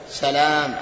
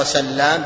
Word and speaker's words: سلام: لكن في النطق يختلف، سلام عبد الله بن سلام، وليس بالتخفيف إلا عبد الله بن سلام كما قال النووي.